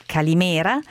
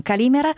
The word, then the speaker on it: Calimera: Ringrazio l'ascoltatore che mi ha segnalato la pronuncia corretta di Calimera e non Calimera.